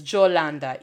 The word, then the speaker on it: Yolanda: If you are in the Caribbean and someone calls you Jojo for short, your name may actually be Yolanda, but it's pronounced as Jolanda, even though it's written with a Y.